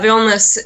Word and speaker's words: Vilnius: Now the town is um, Vilnius. Is that how you pronounce yeah. it? Yeah. Yeah, Vilnius is actually the capital of Lithuania, and right.